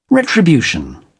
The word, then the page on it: retribution: British English